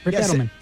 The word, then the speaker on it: Adelman: Is it Rick Adelman? Is that the way you pronounce it? – Yes, Rick Adelman.